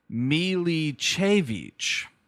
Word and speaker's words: Milicevic: Ivana Milsevich, I think is the idea here. Here we have the how to pronounce it on IMDb. – Here we go. – Milicevic. Ivana Milicevic is this woman.